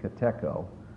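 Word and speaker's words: katecho: It's K-A-T-E-C-H-O, kateko. Actually, I think the Greek is, to be correct, is pronounced kateko, but catechism puts the emphasis on the first syllable.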